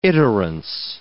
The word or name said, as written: iterance